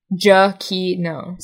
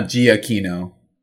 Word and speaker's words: Giacchino: pronounced Giacchino. Giacchino. – So, no, not Giacchino. – Definitely not Giacchino, but Giacchino. Gay icon, though.